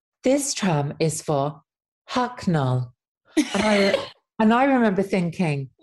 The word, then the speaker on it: Hucknall: Well, first of all, let's get this pronunciation of Hucknall right. So would you like to hear the absolute gold standard of I'd love nothing more. – Hit how the locals pronounce it. So it's Hucknall.